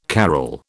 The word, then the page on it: carol: British English